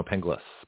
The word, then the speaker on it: Penglis: Is it Pangliss? No, Pengliss. P-E-N-G-L-I-S. That's what everybody mispronounces it. Everybody misspells it. It's only 7 letters. P-E-N-G-L-I-S, you know.